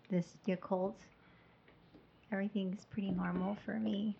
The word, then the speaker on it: Yakult: Yakult.